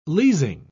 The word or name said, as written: leasing